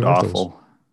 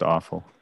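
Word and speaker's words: Erfle: you're looking more at Erfels and Kellners. – Yeah. – I don't think it's pronounced awful. Yeah. I think it's pronounced awful.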